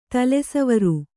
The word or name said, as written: ತಲೆಸವರು